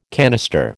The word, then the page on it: canister: American English